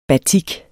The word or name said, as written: batik